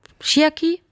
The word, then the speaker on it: Chiaki: Sie spielt zusammen mit Mara, ich hoffe, ich spreche es richtig aus, Chiaki. – Falsch. – Chiaki. – Ganz falsch. – Chiaki? – Ja. – Ach, die Betonung.